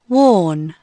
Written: worn

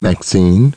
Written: Maxine

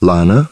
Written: Lana

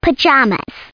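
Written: pajamas

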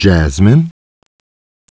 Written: Jasmine

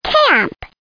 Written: camp

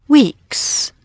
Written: WEEKS

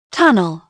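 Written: tunnel

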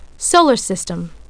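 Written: solar system

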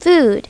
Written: food

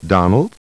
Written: Donald

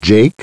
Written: Jake

